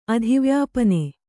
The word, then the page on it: ಅಧಿವ್ಯಾಪನೆ: adhivyāpane